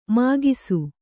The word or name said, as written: ಮೊಗೆಸು